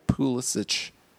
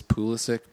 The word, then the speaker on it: Pulisic: I'm pronouncing it the way that they pronounce it in Germany, or he asked them. Did you read that? – Uh-uh. – That he, like, when he's in the U.S., he wants Pulisic, but when he's there, he says Pulisic.